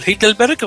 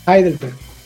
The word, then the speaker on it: Heidelberg: el, el, Hitlerberg o el Heidelberg, ¿cómo se pronuncia? – No, te digo que no. Eh, si no, si, hubiese estado ahí, te hubiese dicho al tiro, mira, eh, fui al, sur, allá a Heidelberg. Heidelberg.